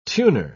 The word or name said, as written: tuner